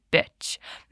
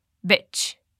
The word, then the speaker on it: bitch: Mas olha a diferença na pronúncia, porque muita gente tem dúvida disso. Beach, praia. Bitch é o xingamento. Beach, bitch. Percebeu a diferença? Então, repete comigo, sem medo de esticar bem esse i.